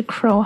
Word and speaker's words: crow: 对，And then be careful because it's also crow，发音是 crow。